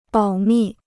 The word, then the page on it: 保密: bǎo mì